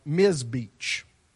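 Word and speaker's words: Ms. Beach (מזבח): The Hebrew word for altar is, I'm going to butcher this, but it's M-I-Z-B-E-A-C-H, like Ms. Beach.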